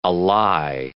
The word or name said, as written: ally